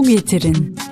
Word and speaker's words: getirin: Lütfen bir su getirin.